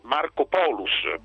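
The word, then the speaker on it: Markopolos: come ha detto Makropoulos, che anzi Marco Polus, perché poi il nome si pronuncia in questo modo, certo.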